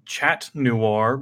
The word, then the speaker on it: chat noir: It's perfectly fine, because I was pronouncing it something that I am informed is not a good word in the original French, chat noir, because once again, I am a terrible American person who doesn't know how to pronounce other languages. And apparently chat in pronouncing it chat in French is not the same as saying cat.